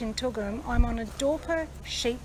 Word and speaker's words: Dorper: Today I went on a mission to try and find out more about Dorper sheep. And have I pronounced that right? – You have. – I have. So I'm here on a Dorper sheep farm in Toolgum.